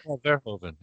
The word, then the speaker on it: Verhoeven: Like, for me, it's and I always pronounce his name wrong. Paul Horhaven, I think, the guy who did Paul Verhoeven. Yes.